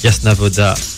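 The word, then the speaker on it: Jasna Woda: Alors Yasna Voda, ça s'écrit J-A-S-N-A espace W-O-D-A. – Exactement.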